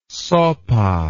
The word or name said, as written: sopa